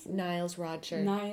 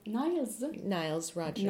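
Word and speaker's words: Nile Rodgers: comment on prononce son nom? Niles? – Niles Rogers. – Niles Rogers.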